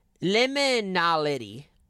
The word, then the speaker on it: liminality: In anthropology, liminality, that's how you say it.